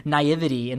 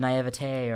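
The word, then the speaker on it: naïveté: I was saying naivety instead of naivete or whatever. It's pronounced. It's naivete, right? – Is that like an 18?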